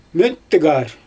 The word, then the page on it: möttygaar: Xyzyl